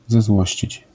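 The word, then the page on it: zezłościć: Polish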